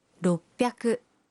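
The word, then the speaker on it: ropiaku (ろっぴゃく): 600 se convierte en ropiaku.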